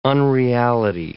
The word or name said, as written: unreality